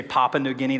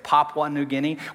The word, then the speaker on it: Papua New Guinea: And I know some of you say Papua New Guinea. That's not how you say it. It's Papua New Guinea.